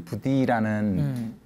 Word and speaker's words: Buddhi: 게 이상해서 약간 좀 I로 썼는데 그럼 발음이 부디가 아니라 여전히 버디 같아요.